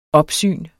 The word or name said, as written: opsyn